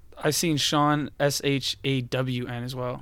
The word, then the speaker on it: Sean: S-E-A-N. This you'd think it'd be pronounced seen, but it's pronounced Sean.